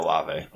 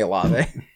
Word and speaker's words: Olave: So it's not Olave. – Olave. Doesn't matter. – It's definitely Olave.